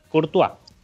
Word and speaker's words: Courtois: ¿Cómo se, pronuncia, el apellido? Ayúdame. Courtois.